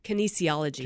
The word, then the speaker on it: kinesiology: What is it? – Kinesiology. – Kinesiology.